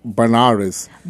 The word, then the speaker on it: Benares: Well, it's actually Benares.